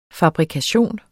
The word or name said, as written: fabrikation